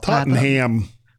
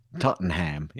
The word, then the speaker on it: Tottenham: Up there in England in Tottenham. – That's not Tottenham. – Tottenham.